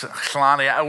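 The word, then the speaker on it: Hlane: And it's Hlani, but they say it with an S, Hlani.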